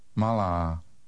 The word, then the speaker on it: malá: Malá. – Did you make the long vowel sound in the second syllable? Remember that in Czech, the stress is always on the first syllable of a word.